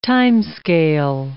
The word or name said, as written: timescale